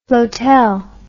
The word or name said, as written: flotel